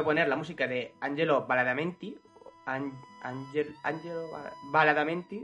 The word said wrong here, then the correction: Badalamenti: no sé muy bien cómo se dice. – Angelo Badalamenti, creo.